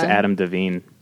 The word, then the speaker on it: Devine: Is that how you pronounce it? – I think so. – Okay. – It's Adam Devine. – That's what, yeah, that sounds right. Like Adam Devine, like a shrimp.